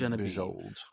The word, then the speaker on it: Bujold: And I'd heard of, how do you say her name again? Genevieve Bejold.